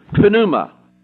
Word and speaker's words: pneuma: Have you ever wondered why in English that the word pneumonia, when you get pneumonia, starts with a P-N? Because it comes from the Greek. Pneumonia is the way we pronounce it, so it would be pneumonia, if you pronounce it with the P. Okay? But in the Greek, it's not that difficult. Pneuma.